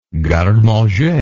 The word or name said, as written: garde manger